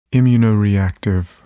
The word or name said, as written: immunoreactive